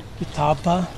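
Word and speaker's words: Ketepa: How is that pronounced? Kitepa? – Kitapa. Yeah. Mm-hmm.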